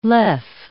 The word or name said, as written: lev